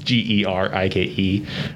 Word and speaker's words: Gerke: You can find me on Twitter mostly at Evan Gerke. I'll spell out my last name because... Obviously, people don't see the pronunciation and put the words together, but it is Gerike.